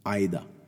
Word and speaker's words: Ai-Da: called Ida, the Ida robot. So this is spelled AI-DA robot.